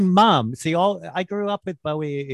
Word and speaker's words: Bowie: Well, you know, David always said rhymes with slowy. – Okay, well, slowy Bowie.